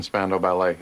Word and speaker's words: Spandau Ballet: Our entire marriage, I never once mentioned Spandau Ballet. Am I even pronouncing that right? – Spandau Ballet.